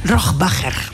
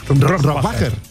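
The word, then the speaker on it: Rohrwacher: Bueno, ¿cómo se pronuncia Alba Rohrbacher Cochet? – Rohrbacher. – Rohrbacher. – Sí.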